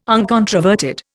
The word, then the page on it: uncontroverted: Indian